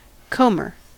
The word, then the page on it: comber: American English